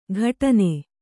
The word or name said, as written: ಘಟನೆ